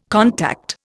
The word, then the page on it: contact: Indian